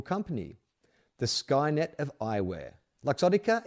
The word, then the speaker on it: Luxottica: Luxottica. Luxottica. Oh, like luxury. Luxottica? Would it be Luxottica or Luxottica? Luxury. L-U-X. Luxottica. Let's call it Luxottica.